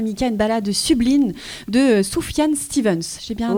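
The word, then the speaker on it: Sufjan Stevens: Soufiane Stevens. – Ouais. – Tu me parles de la prononciation parce que si vous avez envie d'en savoir plus et chercher sur internet, ça s'écrit S-U-F-J-A-N et Stevens, S-T-E-V-E-N-S.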